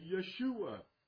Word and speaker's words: Yeshua: Jesus is simply the Greek version of Joshua, and Joshua is not pronounced Joshua. That's how we put it into the English. It's actually Yeshua.